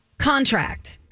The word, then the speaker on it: contract: The noun form is going to stress the first syllable. The verb is going to stress the last syllable, okay? So, for example, contract is something you sign. – Sign a contract. Did you get your contract? Have you signed that contract? – Contract is a verb.